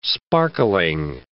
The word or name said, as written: sparkling